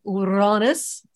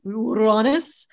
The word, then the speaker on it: Uranus: which was meeting up with Uranus. That's how I pronounce it. Uranus,